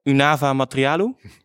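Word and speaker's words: Únava materiálu: And we'll talk with Marek Sindelka, who wrote a beautiful book I'm trying to pronounce it in Czech, Unava Materialu.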